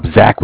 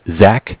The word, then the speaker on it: Zach: Zach with Bank of Cardiff. – What did you say your name was? – Zach. Z-A-C-H. – With who? – Bank of Cardiff. Cardiff is spelled C-A-R-D-I-F-F, like Cardiff, Wales.